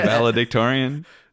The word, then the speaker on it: valedictorian: How do you say it? – The valedictorian.